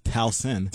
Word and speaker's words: Towson: First of all, maybe my Towson. School, first of all, it's pronounced Towson.